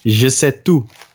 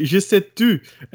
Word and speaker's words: Je sais tout: Based on well, today, before before I answer his question, I do have to give you a mild correction on your pronunciation. I'm sorry. Je sais tout. – Oh, excuse me. It's not, yeah, well, you say je sais tu, and that means I say you.